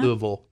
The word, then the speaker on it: Louisville: Louisville is how you say it. Louisville. – Yeah, you have to act like your mouth is full.